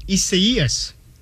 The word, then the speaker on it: Isaias: The correct pronunciation of the hurricane, it's Hurricane Isaias.